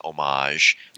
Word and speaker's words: homage: You know, and again, you know, the true linguists out there could possibly correct me, but I believe the correct pronunciation is homage. Okay. But Americans are like, you know, it's not, there's an H in it, so we're going to pronounce it.